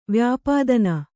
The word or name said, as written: ವ್ಯಾಪಾದನ